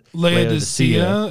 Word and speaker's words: Laodicea: Laodicea is how I had someone pronounce that one, where it's like, it's the hot and the cold equals lukewarm, and God says, I'll spit that out of your mouth.